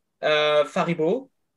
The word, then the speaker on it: Faribault: Nicolas Faribault. – Yeah. – It's a French name. So.